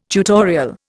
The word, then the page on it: tutorial: Indian